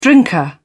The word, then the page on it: drinker: British English